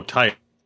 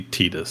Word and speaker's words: Tidus: So forget correct pronunciation if you look at it and say, like, oh, tide. It's, I think, intended to be Tidus.